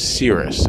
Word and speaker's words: Cerys: And I'm going to bring up my friend Sarah Kaylee, or the real way to pronounce her name is Cyrus. It's spelled C-E-R-Y-S. And it's actually pronounced Cyrus, and it's Welsh, which is interesting.